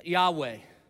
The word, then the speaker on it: Yahweh: And the way it's pronounced, Yahweh, it's supposed to sound like breathing. Breathe in, breathe out. Yahweh.